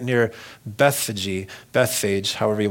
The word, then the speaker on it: Bethphage: And going on, they're coming into this city of Bethphage. I've heard people pronounce it Bethphage. You can do what you want with it.